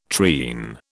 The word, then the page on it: treen: British English